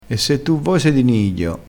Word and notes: di nidio: dialettale

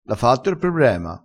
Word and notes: diavolo: dialettale

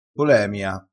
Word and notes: polemia: dialettale

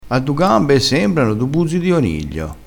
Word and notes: buzzo di oniglio: dialettale

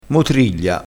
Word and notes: motriglia: dialettale

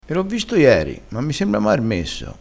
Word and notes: marmesso: dialettale

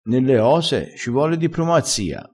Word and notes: dipromazia: dialettale

